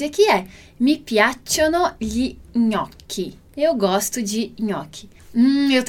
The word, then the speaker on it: gnocchi: E depois a gente tem, né, o clássico prato aí, gnocchi, gnocchi. E vai ter esse nosso som de NH no português.